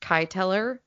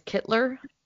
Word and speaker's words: Kyteller: spelled, like it looks like it's pronounced Kyteller, but apparently it's Kittler.